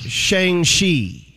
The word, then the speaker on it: Shang-Chi: They're saying it's pronounced Shang-Chi.